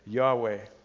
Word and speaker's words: Yahweh: And because the Jewish people would not pronounce the name, nobody really knows how it is to be pronounced. However, most scholars agree that we've probably come as close as we're likely to get when we say Yahweh.